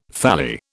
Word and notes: thalli: British English

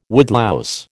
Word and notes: woodlouse: American English

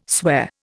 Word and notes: swear: Indian

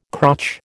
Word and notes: crotch: American English

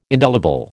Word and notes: indelible: American English

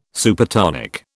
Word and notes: Supertonic: British English

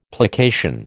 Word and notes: plication: American English

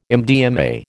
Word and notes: MDMA: American English

